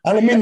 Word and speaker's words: aluminium: Aluminum. Aluminium.